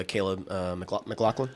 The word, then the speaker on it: Caleb McLaughlin: No, people are praising a number of the performances, particularly Caleb McLaughlin. Is that how you say his name? – Yeah.